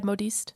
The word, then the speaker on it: modiste: Her shawl was a dull olive color with a bit of silver embroidery, and when her uncle saw the bill from the modiste, is that like a dressmaker? – Okay, am I saying it right? – Yeah, modiste.